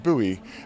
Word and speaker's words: Bowie: Spelt Bowie, but not as in David, pronounced Bowie.